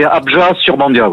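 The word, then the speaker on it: Abjat-sur-Bandiat: Et est-ce que vous, c'est bien Abja sur Bandia, c'est comme ça qu'on doit prononcer ? – Alors oui, on ne prononce pas forcément le T final, c'est Abja sur Bandia, oui.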